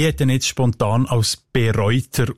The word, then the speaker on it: Bereuter: Ich habe dann aber gesehen, dass da erst gleich B beim Namen Bereuter kein Präfix ist, sondern dass es betont wird und in der Mundart mit einem langen E ausgesprochen B. Und der ganze Name mundartlich Bereuter.